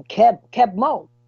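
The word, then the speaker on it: Keb' Mo': But where he comes from in the South, nobody pronounced his name the right way. Everybody called him Keb Mo. So he kept it as Keb Mo.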